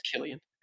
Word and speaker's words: Cillian: It's spelled Cillian. – But it's pronounced Killian.